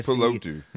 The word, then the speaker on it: Tuipulotu: Tuipulotu, yeah. – Yes, yeah, got the pronunciation down.